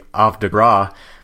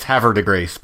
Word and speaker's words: Havre de Grace: There's a town in Maryland not far from here that has a French name, which if it was properly pronounced in France, it would be Havre de Grace, but it's Havre de Grace because that's what the people there call it.